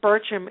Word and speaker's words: Burcham: It's Christine with a C, and my last name is Burcham. It's B-U-R-C-H-A-M.